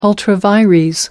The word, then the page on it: ultra vires: American English